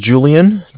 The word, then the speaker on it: Julian: It's Julian, J-U-L-I-A-N. – Okay. – From Bank of Cardiff. It's spelled C-A-R, D as in dog, I-F-F as in Frank Frank.